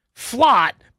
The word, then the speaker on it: Flott: I know it's Flott because it rhymes with slot. Flott, but I think it might be Cordale.